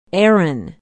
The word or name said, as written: erin